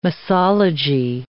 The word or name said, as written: misology